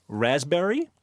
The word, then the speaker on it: raspberry: K-N-E-E-L, Neil. – Raspberry answer. – Silent letters. – Yes, silent letters. Oh, raspberry is? – The silent S. – P. – Well, P. Sorry. – Silent P, right.